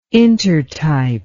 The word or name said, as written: intertype